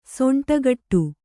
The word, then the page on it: ಸೊಂಟಕಟ್ಟು: Kannada; soṇṭa kaṭṭu